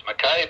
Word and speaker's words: Mackay: We live in a city called Mackay, spelled M-A-C-K-A-Y. Some say it's Mackay, but the guy that founded it was a Scotsman, and he pronounced it I, so I'll pronounce it Mackay.